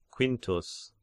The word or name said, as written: Quintus